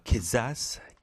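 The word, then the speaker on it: Quizás: Nat King Cole와 Quizzaz, Quizzaz, Quizzaz. 제가 퀴자라고 했는데 이 발음이 그렇지 않네요.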